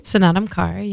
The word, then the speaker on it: Snatam Kaur: Snatam Kaur, yeah.